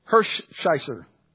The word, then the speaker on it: Hershiser: His name was Oral Hershiser.